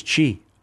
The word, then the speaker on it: Chi: Wow, I thought it was chi. I didn't know it was ki. – Well, it can be pronounced different ways. I mean, they spell it C-H-I, but a lot of times it's pronounced ki.